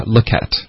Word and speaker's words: look at: 然后也有 take a look at，然后它的连读呢就是都是一样的，因为都是发 look at，所以它应该是发 look at， look at，你可以想成用一个倒过来的一的这个发音，一个的发音。可是在美音里面呢也经常听到另一个发音，就是一个大写的 i，然后这个发音是 i，就是有点像 it 的这个发音。对，不是 e，是 i。